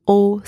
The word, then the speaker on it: haut: The second word, l'eau, spelled E-A-U, means water. And the letters E-A-U together are also pronounced O. And finally, O, spelled H-A-U-T, means high or tall. The letter H is silent, and again, the letters A and U together are pronounced O, and also the final consonant in that word, the T, is silent as well.